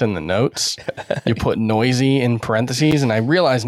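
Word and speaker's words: Neuse: By the way, if you're trying to figure out how to spell that, that's N-E-U-S-E, and it's pronounced noisy.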